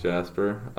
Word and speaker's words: Jasper: Hi, my name is Jasper. It's spelled J-A-S-P-E-R.